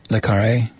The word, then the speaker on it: Le Carré: Is that how you pronounce it? Le Carré? Yeah.